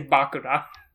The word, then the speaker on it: Bakura: Okay, so maybe just to be clear, we should be using the Japanese pronunciation and just say Bakura.